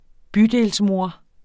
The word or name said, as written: bydelsmor